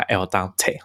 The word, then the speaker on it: Aldente: 其实一开始我都不知道这个 App 要怎么发音哦，但是栗子说这就是要用意大利文的方式，所以我就乱发音，就叫它 Al Dante 哈。它的拼音是 A L D E N T E。